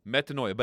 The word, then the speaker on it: metanoia: But the word is metanoia, or metanoia. I got a little bit too much syllable in there. Metanoia, but it means, you know what, you remember what it means.